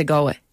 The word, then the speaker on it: Galway: Well, actually, it was brought to my attention that like people from Galway don't pronounce the L in Galway. They just say Galway.